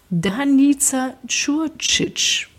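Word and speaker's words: Danica Ćurčić: ist also, die haben durchaus auch äh, da ah, internationales ah, nee, nicht, Vollkommen falsch ausgesprochen. Danica Kurcic weil nämlich original aus Belgrad.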